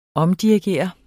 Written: omdirigere

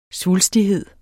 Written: svulstighed

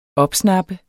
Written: opsnappe

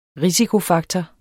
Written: risikofaktor

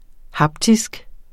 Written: haptisk

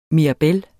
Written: mirabel

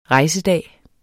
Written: rejsedag

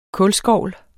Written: kulskovl

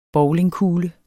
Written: bowlingkugle

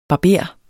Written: barber

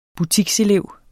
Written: butikselev

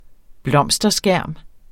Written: blomsterskærm

